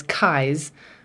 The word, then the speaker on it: Keyes: And then my last name, my maiden name, is Keyes, which is spelled K-E-Y-E-S.